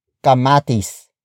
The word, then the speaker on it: kamatis: Ilan ang kamatis sa basket? Ilan ang kamatis sa basket? Ilan ang kamatis sa basket? It means, how many tomatoes are there in the basket? Word for word, it's ilan, ang, kamatis, sa, basket.